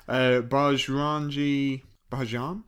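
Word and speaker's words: Bajrangi Bhaijaan: So let's try and pronounce it right now. Bajrangi Bajan. – That's exactly how I pronounced it last week.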